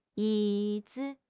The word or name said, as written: yi3 zi5